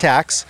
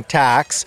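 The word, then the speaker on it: Tkacs: So it's going to be Brett Tax.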